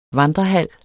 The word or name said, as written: VANDREHALS